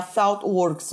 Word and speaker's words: Thoughtworks: Existem empresas hoje, vou citar uma delas, que é a Thoughtworks ou ThoughtWorks, né? Tem, tem as duas pronúncias.